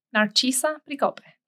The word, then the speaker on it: Narcisa Pricope: A lot of people call me Dr. P, so that's perfectly acceptable, but you would pronounce it Pricope. Narcisa Pricope.